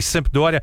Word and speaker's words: Sampdoria: Sampdoria, Tá certo, tá certo.